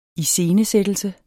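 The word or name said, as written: ISCENESÆTTELSENS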